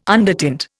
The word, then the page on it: undertint: Indian